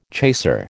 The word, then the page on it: chaser: American English